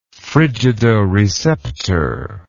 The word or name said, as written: frigidoreceptor